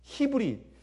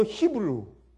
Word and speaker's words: 히브리: 히브리. 히브리. 여러분 영어로 히브루. 근데 원래 그 그러니까 히브리어로 히브리어로 옛날 아람어로 발음이 히브리예요.